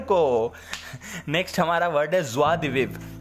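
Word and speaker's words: joie de vivre: but ऐसे है इसका प्रोनाउंसिएशन असली में जो है उसको बोलते हैं ज्वा दिविव ज्वा ज्वा स्वाहा स्वा उसे ज्वा दिविव ज्वा दिविव विव बोल के छोड़ देते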